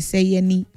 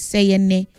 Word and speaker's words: Seyene: Say my name no be Seyene, is Seyene, S-E-Y-E-N-E, okay?